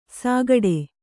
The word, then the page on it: ಸಾಗಾಡೆ: sāgāḍe